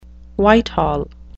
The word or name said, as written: whitehall